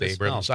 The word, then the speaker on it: Abrahams: They have it Abrahams. Am I just saying it wrong? Is it pronounced Abrams? – It's pronounced Abrams.